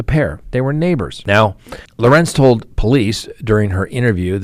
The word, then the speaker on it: Lorincz: So please meet, and I think I'm pronouncing her name correctly, Susan Lorenz, L-O-R-I-N-C-Z. Lorenz, that's where we're going with.